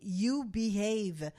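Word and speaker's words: Eubie Hayve: C'est très drôle parce que la façon dont c'est écrit, faut vraiment le prononcer pour deviner que c'est You Behave. Mais tu sais, ça s'écrit E-U-B-I-E. Après ça, un autre mot, H-A-Y-V-E.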